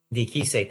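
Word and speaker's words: Diakiese: He's a plus 230 underdog against Mark Diakis. Am I saying that right? – Diakise, thank you.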